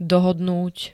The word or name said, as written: dohodnúť